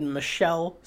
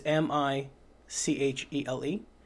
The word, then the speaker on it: Michele: So it's directed by I've always said Michele Soavi, because it's M I C H E L E, which is Michelle. – Yes, But I heard it pronounced as Michele. – Oh, that does sound a little more Italian than...